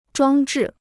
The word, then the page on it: 装置: zhuāng zhì